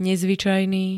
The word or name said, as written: nezvyčajný